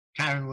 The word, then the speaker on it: Kyren: Kyren Williams.